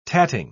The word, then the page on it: tatting: British English